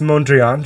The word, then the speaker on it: Mondrian: No, it's not Data's painting of the Enterprise hallway or his one that's the boxes and lines that's basically Mondoran's evolution. – I was wondering if you were about to take horse playing saxophone. – It's pronounced Mondrian, just for the art nerds.